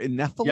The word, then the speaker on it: Nephilim: I swear, Nephilim? – Yeah, you're saying it right.